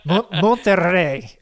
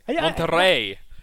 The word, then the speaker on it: Monterrey: By the way, I just want to let you know, I really enjoyed the way you roll your R's on... I'm terrible at it. Monterrey. – Monterrey.